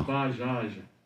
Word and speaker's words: Bajaj: Ba. Jaj. Bajaj. – Yeah. – Bajaj. – Yeah. – And he spells it B-A-J-A-J.